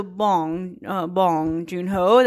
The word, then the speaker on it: Bong Joon-ho: My friend who speaks fluent Korean, she corrected me on how to say his name. I was saying it wrong. Okay, but the way I just said it was correct. Okay, so Bong Joon-ho, that's his name.